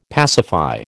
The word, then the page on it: pacify: American English